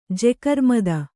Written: ಜೆಕರ್ಮದ

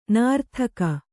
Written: ನೀರ್ತೆಗೆ

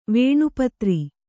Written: ವೇಣುಪತ್ರಿ

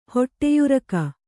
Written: ಹೊಟ್ಟೆಯುರಕ

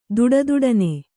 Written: ದುಡುದುಡನೆ

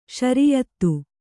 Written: ಶ್ರೀಯುತ

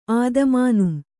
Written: ಆದಮಾನುಂ

